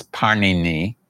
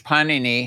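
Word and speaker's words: Pāṇini: And of the three grammarians who reorganized the language, the one who is best known, his name was Panini. And Panini, kind of like the, I mean, his name is very much like the Italian bread, Panini. It's pronounced slightly different.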